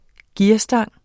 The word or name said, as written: gearstang